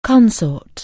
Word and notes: consort: anglais

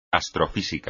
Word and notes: astrofísica: espagnol

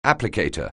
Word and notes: applicator: anglais

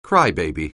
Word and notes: crybaby: anglais

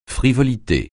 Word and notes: frivolité: francais

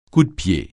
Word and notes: cou-de-pied: francais

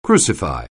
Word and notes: crucify: anglais

